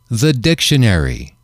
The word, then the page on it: the dictionary: male